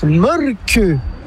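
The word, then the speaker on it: Mölkky: Inventé en Finlande en 1996, ce jeu de quilles s'écrit M-O-T-R-E-M-A-L-K-K-Y. – Ça s'écrit Molki, mais ça se prononce Molke. – Le Molke. – Plutôt un E qu'un E, mais pas un U. Donc ça fait Molke.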